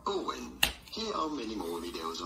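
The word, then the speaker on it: Eoin: Well, his name is spelled E-I-O-N, and... Owen. Yeah, and well, you would rightly recognize that as being Owen, but the majority of the rest of the Earth, it's Ian, it's Owen.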